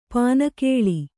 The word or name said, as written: ಪನಿಗೊಳ್